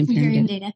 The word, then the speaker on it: Imperium Data: And so then you started, well, once you came to Imperium Data, am I saying that right? – Imperium Data. – Okay. I didn't want to like be saying the wrong thing. – You got it.